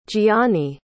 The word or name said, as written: jionni